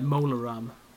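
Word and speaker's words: Mola Ram: Mularam. M-O-L-A-Ram. So, yeah, Mularam.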